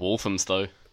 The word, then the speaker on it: Walthamstow: Walthamstow.